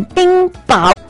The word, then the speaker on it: 冰雹: 哎呀，我看看冰雹。对不起，我两个都念错了，冰雹，对，